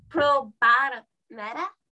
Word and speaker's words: probármela: Oh, you pronounce the L. – Yeah, probármela. – Oh, you only don't pronounce the L if there's two L's. – Yeah, there's two L's, it's yeah. – Okay. – Like a Y sound. Yeah, but this is one L, so it's probármela.